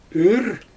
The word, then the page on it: yyr: Xyzyl